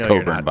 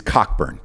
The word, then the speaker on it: Cockburn: And yeah, I'm not pronouncing it Coburn, by the way. – I will never fucking do that ever. It is Cockburn.